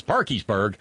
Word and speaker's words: Parkersburg: Eric Eugene Barber is, of course, a former city councilman, want to guess his politics, from Parkersburg, That's how we pronounce it here. It's Parkersburg, but it's Parkiesburg, West by God, Virginia.